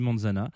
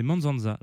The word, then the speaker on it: Manzanza: Alors, j'ai longtemps dit Manzana. Et puis je me suis rappelé qu'il fallait quand même pas écorcher les noms de famille, Jean Fromageau. Hein c'est quand même important, espèce de petit con. Donc voilà, c'est Manzanza. Alors peut-être que c'est pas comme ça exactement que ça se prononce, mais c'est comme ça que ça s'écrit.